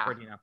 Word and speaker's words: Guardino: And they go, and Henry, how do you say his last name? Guardino, I think. Yeah. – Guardino.